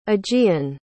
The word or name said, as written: aegean